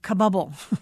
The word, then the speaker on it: Kabul: Christina, you have a chapter about Kabul.